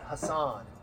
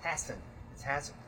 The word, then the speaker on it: Hassan: Guys, it's not Hassan. It's Hassan.